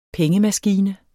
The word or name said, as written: pengemaskine